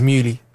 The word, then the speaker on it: Muli: Like, some people say Mully, innit? – Yeah. – But you said Mully. – Yeah, Mully. – Yeah, yeah. But it's Muli.